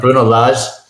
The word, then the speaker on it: Bruno Lage: Now, we mentioned all of these stats, and do we think really Bruno Lage, thanks for the correct pronunciation last week, Mariner. That was well done. Bruno Lage. – It was a guess. – It was a guess. You guessed right.